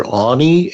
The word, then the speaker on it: Aune: It's Christopher Aune, and it's spelled A U N E.